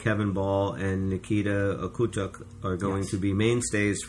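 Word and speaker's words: Okhotiuk: So, Okotiuk. Okay, it's O-K-H-O-T-I-U-K. Nikita Okotiuk.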